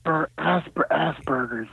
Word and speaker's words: Asperger's: We're starting a little late, but the world has stated that you have, is it Asperger's? Am I pronouncing that correct? – Asperger's, yes.